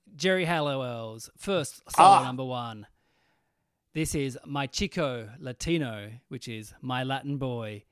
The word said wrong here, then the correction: Mi Chico Latino: Firstly, I need to correct you. It's Mi Chico pronounced. – Mi Chico Latino, Mi Chico.